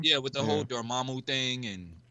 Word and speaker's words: Dormammu: like am I saying it right? Dormammu? – Yeah. – Is it Dormammu? – Yeah, there you go. – Dormammu. – No, yeah, no, you got it the first time.